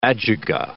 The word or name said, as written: ajuga